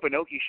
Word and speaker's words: Inoki: And then today they had a press conference to put in, how do you say his last name, Inoki? Is that right? – Oh, yeah, Antonio Inoki. Okay.